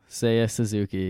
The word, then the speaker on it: Seiya Suzuki: Help me if I pronounce it incorrectly, but Seiya Suzuki. – Seiya Suzuki.